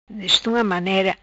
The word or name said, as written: Destunga manera